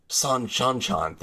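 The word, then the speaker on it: Seanchan: which is Senchan. – Oh, the Shanchan. – Shanchan. Thank you. – Yeah, okay. It's because it's C-E-A-N as in Sean.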